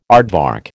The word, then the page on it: aardvark: American English